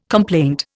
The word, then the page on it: complaint: Indian